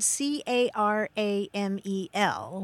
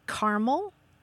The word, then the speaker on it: caramel: Yeah, I mean, we're talking about the word C A R A M E L, which looks like it has three syllables, but in the United States, some people say caramel, some people say caramel, some people say caramel, and some people actually say caramel.